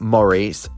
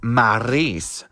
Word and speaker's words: Maryse: It's not Maurice, it's Maryse.